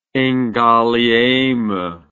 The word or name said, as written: Ngaliema